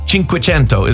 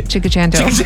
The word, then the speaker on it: Cinquecento: Okay, so it's an Italian word. It means 500. Cinque is five and cento is 100. Cinquecento is how you actually pronounce it.